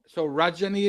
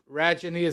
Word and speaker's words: Rajani: But I tell people when they're confused about how to pronounce my name, imagine it rhyme. The first part rhymes with fudge. So Rajani, Fudgeny. So fudge. How's that? – So Rajani is your name? – Yes. – Not Rajani, as Americans might say.